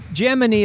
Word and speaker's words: Gemini: Gemini is how the 60s astronauts pronounce it.